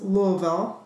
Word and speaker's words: Louisville: And yes, it is pronounced Louisville. I learned that when my son Jacob was down in school in Kentucky, when I started to say Louisville, and people didn't like that.